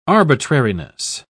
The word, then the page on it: arbitrariness: British English